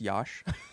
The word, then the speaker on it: Josh: You know his name is actually pronounced Yosh? – It's Yosh Homme. Yes, it's Yosh.